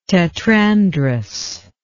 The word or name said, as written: tetrandrous